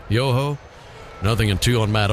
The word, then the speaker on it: Yoho: Y-O-H-O, Yoho. Pronounced exactly as you would expect.